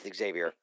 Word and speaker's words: Xavier: As his lawyer pronounces it, Xavier. He does a hard R with Xavier.